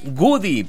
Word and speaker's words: Woody: Es Woody, pero se escribe de la siguiente manera, por si hay algunos que no nos encuentran ya en el Face. Les recuerdo que estamos como el Woody Morning Show. Woody se escribe con W. Y luego vamos a poner una O, otra O, una D y una Y. Hay cuenta que dice Woody. Sí, y se pronuncia Woody.